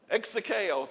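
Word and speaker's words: execheo: In the Greek, it's E-X-E-C-H-E-O. Exekeo.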